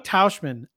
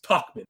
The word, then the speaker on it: Tauchman: Mike Tauchman. Oh, my God. – I got to correct you for our boy Alex. It's Tauchman.